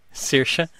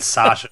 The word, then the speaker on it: Saoirse: So I was totally thrown by the normal speaking voice of Saoirse Ronan. – Saoirse. Yeah, I don't think James Corden got her name right either. I think he said Sasha, but yeah. – Saoirse.